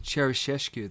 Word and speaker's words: Ceaușescu: Ceaușescu, thank you.